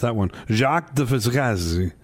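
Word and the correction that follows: Jacques de Vaucanson: Jacques de Vaugansan.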